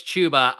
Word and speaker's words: Chuba: It's Chuba. I was corrected early on in this off season. Cause I always said Chuba, but it's Chuba. – Really? I guess because it is spelled like Tuba. So I guess it does make sense. But I just assumed it was like Chuba Hubbard, like Chubby Hubby.